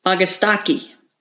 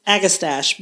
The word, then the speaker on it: Agastache: This was when we were at Terra Nova, and he said, you guys need to pronounce this Augustaki because it is Greek, not Latin.